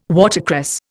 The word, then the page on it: watercress: Indian